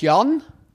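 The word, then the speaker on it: Jann: Mein Name ist Jan Billeter.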